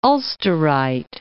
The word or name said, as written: Ulsterite